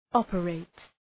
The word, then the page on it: operate: ‘ɒpə,reıt